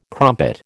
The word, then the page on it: crumpet: American English